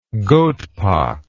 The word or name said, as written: goatpox